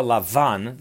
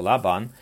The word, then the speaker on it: Lavan: The Hebrew word for Lavan, the biblical pronunciation for Laban, means white.